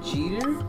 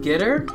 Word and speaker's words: GETTR: It is spelled G E T T R. Now pronounce it. G E T T R. Jeter? Make that G the hard G, not the soft G.